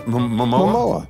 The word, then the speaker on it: Momoa: Jason Momoa.